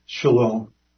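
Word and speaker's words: shalom (שָׁלוֹם): When we look at these four letters, the shin, it pronounces as sh, the lamed is pronounced like an L, the vav is silent, and the M is pronounced like an M. That's where we get the word shalom.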